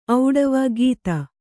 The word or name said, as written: ಖತ